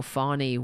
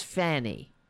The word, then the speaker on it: Fani: they are deliberately mispronouncing her name uh, as Fanny because they're infants, they're babies.